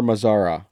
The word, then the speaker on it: Mazara: I understand the move to go get a Mazara, whatever. Is that how you say his name? – Yeah, Nomar Mazara.